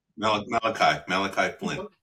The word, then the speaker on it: Malachi Flynn: The Knicks received Anunoby, Malachi Flynn. Is that how you pronounce it? – Malachi Flynn. – That's it.